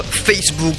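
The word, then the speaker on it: Faithbook: Voici le livre de foi Facebook aussi, mais ça s'écrit F-A-I-T-H, un jeu de mots en anglais.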